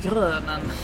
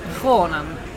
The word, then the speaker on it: Kronen: Jag tror att jag har gått runt och sagt grönen det senaste dygnet. Men Kronen, det är alltså så man, man uttalar svenskdödarens namn.